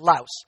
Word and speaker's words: Laos: I actually had to look it up to make sure I was saying it right, but it's called Laos.